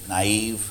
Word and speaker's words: naive: To her, anyone who believed in demons was putting it to her was naive. How do you pronounce that now? Naive. Thank you very much.